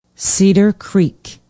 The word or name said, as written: cedar creek